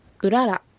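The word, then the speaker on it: ウララ: うららあって、うららあって、このうらら、最後のらは軽く発音してください。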